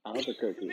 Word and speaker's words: Albuquerque: Albuquerque.